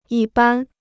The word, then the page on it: 一般: yī bān